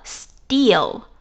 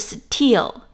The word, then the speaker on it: still: 接下来讲第三个单词叫 still，就是在 till 前面加上 s，s t i l l，也是两个 l，读作 still，不是 still。英文当中一些清辅音放在 s 后面的话，要把它浊化，不是 still，而是 still。